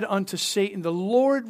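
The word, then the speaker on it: Satan (שָׂטָן): And it translates into English S-A-W-T-A-N. And it would be pronounced Satan.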